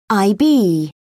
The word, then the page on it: ib.: British English